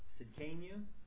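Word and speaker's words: Tsidkenu: Just pronounce the S-I. – Sidkenu.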